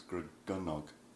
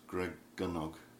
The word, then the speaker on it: Gregynog: The pronunciation of Welsh is always on the penultimate syllable, so it's Gregynog. A little bit like the Italian, Gregynog. – Not Gregynog, which is what you might... – Not Gregynog or Gregynog or anything like that. G-Y-N is always pronounced gun, so it's Gregynog, Gregynog.